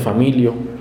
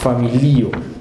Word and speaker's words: familio: Un error muy común en las primeras clases de Esperanto es, para hispanoparlantes, es, por ejemplo, familio, se pronuncia familio. erróneamente, sin embargo, la pronunciación correcta es familio.